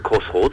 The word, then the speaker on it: X-Roads: Ben, on continue à prononcer Crossroads, puisque le X, en fait, c'est la croix, en quelque sorte.